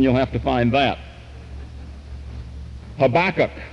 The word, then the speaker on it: Habakkuk: Habakkuk, H-A-B-A-K-K-U-K, that's not Habakkuk, as some of the rest of you have called it. That's the Episcopalian pronunciation. The correct pronunciation is Habakkuk.